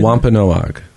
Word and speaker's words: Wampanoag: How do you pronounce that Indian name? You keep mentioning the Wampan. How do you say Wampanoag. – That? Okay.